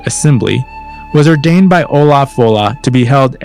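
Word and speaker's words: Ollamh Fodhla: And by the way, the compilers there say that Olam Fodla should actually be pronounced Olav Fola.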